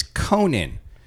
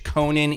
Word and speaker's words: Conan: And it's like, it's not Conan. It's Conan. Robert E. Howard knew his Irish heritage. Conan is an Irish word. It's Conan. – And he's like, oh, Conan is kind of...